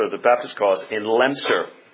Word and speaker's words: Leominster: Notice that's what I'm told is the pronunciation if you're there. It's not Leominster. One of the glories of the English language is that words aren't pronounced the way they look.